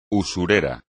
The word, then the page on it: usurero: espagnol